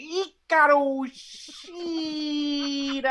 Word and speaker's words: Hikaru Shida: You have to say it like how she said the name. Hikaru Shida! – I honestly don't think Dash is saying it right.